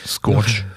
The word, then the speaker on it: Scourge: Scorch. Wie wird das ausgesprochen? S-C-O-U-R-G-E. – Habe ich noch nie gesehen, das Wort. Scorch. – Scorch. Genau.